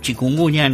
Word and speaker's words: chikungunya: Sí, en este año particularmente estamos pidiendo la limpieza fundamentalmente con estas cuestiones del dengue, el zika, el chikungunya, no sé, sí, si lo digo bien.